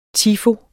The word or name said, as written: tifo